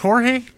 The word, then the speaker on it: Jorge: Is it pronounced Jorge? – Yes.